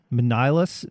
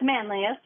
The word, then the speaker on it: Manlius: Manlius, newly functional nutrition. Did I say that right? – It's Manlius. – Manlius, yeah.